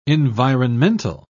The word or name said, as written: environmental